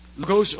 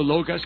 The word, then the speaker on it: logos: The word, now the word in Greek is logos, or logos can be pronounced either way.